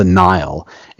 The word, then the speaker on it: Nihil: I think the the Nihil. It's it's sound it's spelled differently, but it sounds like the Nile River.